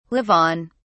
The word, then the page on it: live on: British English